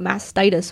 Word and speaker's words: mastitis: And I had no idea what mastitis was.